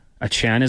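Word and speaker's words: Achane: Achan is the pronunciation we're going with because he did correct the media.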